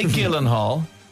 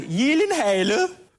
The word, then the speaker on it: Gyllenhaal: Some people say Gyllenhaal. Oh God, no. This is how you pronounce his name. – It is actually Gyllenhaal. – His name is Jake Gyllenhaal because it's a Swedish name.